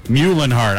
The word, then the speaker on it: Muehlenhardt: Spell Buhlenhart for me, Lee. – Muehlenhardt. – All right. – Muhlenhart. I think I was saying Buhlenhart because somebody made it sound like a B.